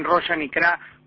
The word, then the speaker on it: Rosh Hanikra: En Jerusalén, siguiendo los acontecimientos que bien has detallado, mañana el escenario de los hechos será el norte, lo pronunciaste bien, Rosh Hanikra,